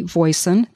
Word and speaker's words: Voisin: And my username on all of those sites is Vicki Voisin. That's spelled V-I-C-K-I, V-O-I-S-I-N, no spaces, no symbols.